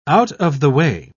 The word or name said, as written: out-of-the-way